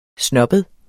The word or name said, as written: snobbet